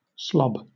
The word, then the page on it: slob: British English